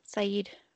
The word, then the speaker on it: Syed: Adnan Syed? Is that how you say his name? Syed. Yeah. Okay.